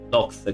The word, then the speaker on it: Docs: Nel 2020 il film ha vinto il Rogers Audience Award al Hot Dog Canadian International Documentary Film. No, credo Dogs si pronuncia, non Dog, Dog è cane. Docs, credo che sia documentario.